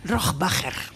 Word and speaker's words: Rohrbacher: Bueno, ¿cómo se pronuncia Alba Rohrbacher Kochet? – Rohrbacher.